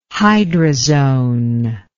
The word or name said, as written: hydrazone